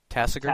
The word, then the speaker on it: Tasigur: The first one is Tasigur. Is that how you think that's pronounced? – Tasigur.